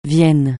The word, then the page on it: Vienne: francais